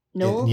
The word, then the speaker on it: Noel: Noel? Yeah, his name's pronounced Noel. – Not Noah.